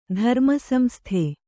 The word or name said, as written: ಧರ್ಮಸಂಸ್ಥೆ